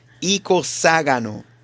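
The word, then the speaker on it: icoságono: Tiene el acento en la A, ¿ok? Icoságano.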